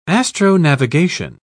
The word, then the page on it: astronavigation: British English